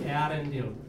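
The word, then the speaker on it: Eärendil: Ale správně by to mělo být Earendil.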